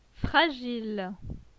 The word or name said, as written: Fragile